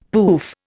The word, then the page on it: poof: Indian